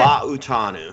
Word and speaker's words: Fa'utanu: Troy Fa'utanu, I believe. – Perfect. You did Fa'utanu. Fa'utanu. Yep. You just have to be slow and pronounce every vowel.